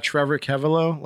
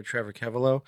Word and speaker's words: Trevor Keveloh: Yeah, Trevor Keveloh. Last name is spelled K-E-V as in Victor, E-L-O-H, all together, Trevor Keveloh.